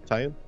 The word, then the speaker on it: Tynan: I never know how to pronounce his name. Is it... I'm Tynan Sylvester, I guess? – Tynan.